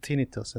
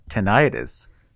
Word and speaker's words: tinnitus: So when I was doing my ENT rotation, I actually pronounced it as tinnitus. And I was corrected right away to the correct pronunciation for this word is tinnitus, not tinnitus.